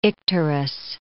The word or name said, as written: icterus